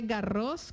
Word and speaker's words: Garros: Ahí va la primera casterita, se dice, bueno, se dice Garros con S al final porque es nombre ¿sí? propio. Sí, en francés la S no se pronuncia, pero esta sí.